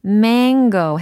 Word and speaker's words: mango: 뭔가 코 소리가 이렇게 맹맹하게 나야 됩니다. 예, mango 해보세요. mango, 코 맹맹이 소리를 내면서 mango. 네, mango 잘하셨어요. 그래서 mango, 이렇게 짧게 발음하시면 안 되고 mango, mango 이렇게 길게 발음을 해주시는 것이 또 하나의 포인트가 되겠습니다.